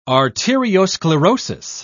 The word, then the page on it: arteriosclerosis: British English